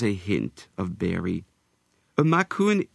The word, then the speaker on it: Macoun: Macoun can be pronounced macaon, but I prefer the French-sounding macoun.